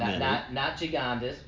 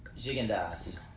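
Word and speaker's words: Gigondas: Yeah, we did get some pronunciations wrong. – That is correct. – Not Gigandas, but Gigandas.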